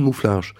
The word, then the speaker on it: Mouffarge: C'est un nom qui s'écrit à l'origine Mufflars. Mais quand il est prononcé par un Polonais, ça donne Mouffarge.